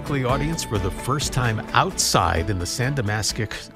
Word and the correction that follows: San Damaso: I'm not saying it right, Ashley Nerona, in the courtyard of the Vatican. Mm-hmm. What's the name of that? I can't speak today. – Well, it's the San Damaso courtyard.